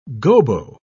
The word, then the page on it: gobo: British English